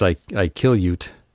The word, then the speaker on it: Iqaluit: A little place in Canada, I think it's Iqaluit, is how the locals pronounce it.